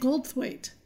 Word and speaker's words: Goldthwait: Bobcat Ghostwise. – Oh. I don't know why you're saying it like that. – How do you say his name? – It's Bobcat Goldthwait. – Goldthwait? – That's right.